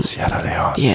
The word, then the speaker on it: Sierra Leone: En zij zijn met een team vertrokken naar Sierra Leone. Zeg ik het goed? Sierra Leone. – Sierra Leone.